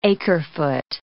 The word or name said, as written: acre-foot